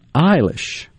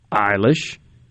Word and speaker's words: Eilish: Eilish. The singer Billie Eilish. E-I-L-I-S-H.